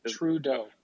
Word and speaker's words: Trudeau: Trudeau. – Close. Trudeau. – Trudeau. I should just think it's like true because it's like the opposite of everything.